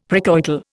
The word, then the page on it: precoital: Indian